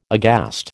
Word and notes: aghast: American English

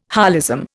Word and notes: holism: Indian

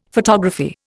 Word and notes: photography: Indian

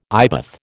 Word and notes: eyebath: American English

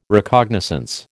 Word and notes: Recognizance: American English

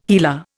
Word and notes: HeLa: Indian

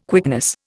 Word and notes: quickness: Indian

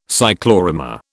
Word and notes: cyclorama: British English

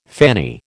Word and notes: fanny: American English

